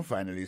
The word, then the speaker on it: finally: Finally, c'est F-I-N-A-L-L-Y, finally. T'as des prononciations, oui? Finally. Accent tombé sur le premier syllabe là. Finally. F-I-N-A-L-L-Y.